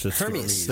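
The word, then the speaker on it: Hermès: It's actually Hermes. – Hermes. – The Greek okay. – god. – I don't want to say it the wrong way, sir. – Hermes.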